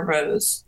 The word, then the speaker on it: Roze: So it's Monica Superior Roze, and the Roze is spelled R-O-Z-E.